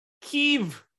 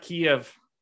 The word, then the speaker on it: Kyiv: Yeah, I pronounce it Kyiv, not Kiev. I'm Ukrainian. Yeah, that's a new one, right? – No, not for me. I'm Ukrainian. I've been well aware. – Wait, so you go by the new or the old pronunciation? – I go by Kyiv as a Ukrainian. I believe Kiev is Russian speak for my area.